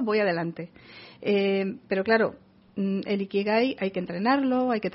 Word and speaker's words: Ikigai: Y lo arrancamos buscando nuestro Ikigai.